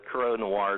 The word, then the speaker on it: Corot Noir: Yeah, Corot Noir, and we hear that pronounced about every which way that there is. So, but Corot Noir is what we pretty much stay with.